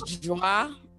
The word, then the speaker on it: Joi: Sometimes people call me Joey, or I think in French it's Joi, because it usually has an E when it's in French.